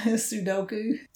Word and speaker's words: Sudoku: Sudoku.